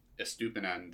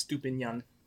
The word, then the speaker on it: Estupiñán: I think the obvious choice is Estupiñán, the Brighton fullback. – He's actually an Enye. It's Estupiñán.